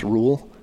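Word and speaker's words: Rhule: But, what's interesting, the new coach, is it pronounced Rule? – Yeah, from Temple.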